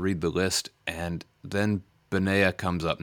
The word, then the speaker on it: Benaiah: So I'm pronouncing it Benaiah. – You pronounce it however you pronounce it. – Yeah, kind of like Isaiah.